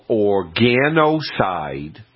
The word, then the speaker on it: Organocide: It's called Organocide, and it's spelled O-R-G-A-N-O-C-I-D-E, 3-in-1.